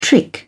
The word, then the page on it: trick: British English